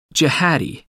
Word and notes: jihadi: American English

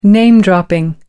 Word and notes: name dropping: American English

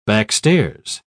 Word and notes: backstairs: British English

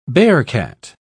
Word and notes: bearcat: British English

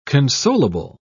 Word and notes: consolable: British English

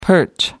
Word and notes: perch: American English

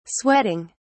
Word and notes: sweating: British English

